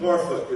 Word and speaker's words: Norfolk: Norfolk is how the locals say it.